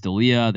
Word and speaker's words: D'Elia: D'Elia? – Chris D'Elia. – D'Elia.